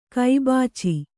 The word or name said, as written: ಕೈಬಾಚಿ